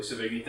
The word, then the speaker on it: Sevigny: Yep, Chloe Sevigny.